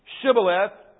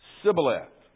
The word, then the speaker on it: Shibboleth: And the other group, one group says Shibboleth, the other group can't say it right, and so they say Sibboleth.